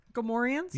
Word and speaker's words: Gamorreans: The Gremors. – Gamorreans. – Yeah. Gamorreans.